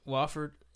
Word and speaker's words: Wofford: Wolford, or am I saying that right? – Wofford. Yeah.